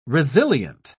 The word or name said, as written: resilient